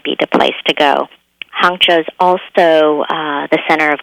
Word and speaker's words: Hangzhou: And you also mentioned Hangzhou. – And that's spelled H-A-N-G-Z as in zebra, H-O-U.